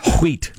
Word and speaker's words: wheat: Once you pronounce the H and once you didn't. I like it when you do pronounce the H. – Okay, fair enough. Wheat.